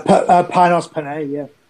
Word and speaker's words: Panos Panay: Panos Panay.